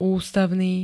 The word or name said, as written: ústavný